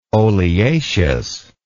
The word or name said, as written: oleaceous